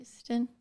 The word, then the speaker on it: Euston: Euston. – Because I used to live in Euston as well. She always puts a very emphasis on the EU becomes a hue.